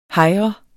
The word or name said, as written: hejre